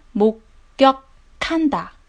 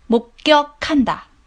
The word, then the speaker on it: 목격한다: 发音 목 격 한 다， 목 격 한 다，目睹，目击。